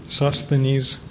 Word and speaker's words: Sosthenes: Was Sophonies, is that how it's pronounced? Sophonies? – Sosthenes. There's an extra S in there.